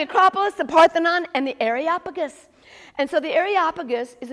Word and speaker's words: Areopagus: It's Areopagus, Areopagus.